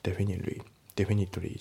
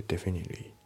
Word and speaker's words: Definitely: で、あと発音ですけど、 Definitely の T がね、抜けますね。 Definitely じゃなくて Definitely、ね、なんていう、これも前も言いましたけど、この TOEIC の問題の、まあ、リスニングにおいてそんな難しい問題って出ないんですよね。